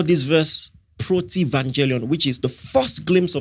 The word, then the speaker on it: protevangelium: There is something theologians call protevangelion, which is spelled P-R-O-T-E-V-A-N-G-E-L-I-U-M. Protevangelion.